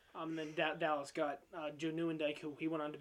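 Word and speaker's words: Nieuwendyk: It was an honorable Joe Newendike. Yeah, Newendike. That's how you say it.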